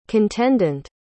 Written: contendent

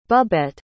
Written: bubbette

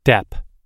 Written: dep

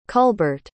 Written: culbert

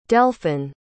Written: delphin

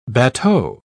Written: bateau

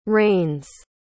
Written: reigns

